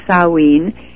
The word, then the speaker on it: Samhain: So this was the god that was celebrated by the druids, by the priests and the Celts, was Samhain, pronounced Samhain.